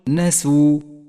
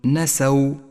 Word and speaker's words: نسوا: ثم بعد ذلك احذر من اللحن عندما تقرأ كلمة نسوا، فاليوم ننساهم كما نسوا، فالسين مضمومة وليست مفتوحة، قل نسوا ولا تقل نسوا، ولاحظ بعدها ألف التفريق الرمادية، لونت بالرمادي إشارة إلى أنها لا تلفظ.